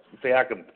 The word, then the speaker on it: Siakam: Siakam, thank you for correcting my pronunciation, because I haven't been able to watch enough Raptors games this season.